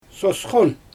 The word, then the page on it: sosxon: Xyzyl